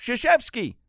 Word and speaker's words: Krzyzewski: There is no S-H in Krzyzewski's last name. – No. – It starts with a K, mm-hmm. – But it's pronounced Krzyzewski.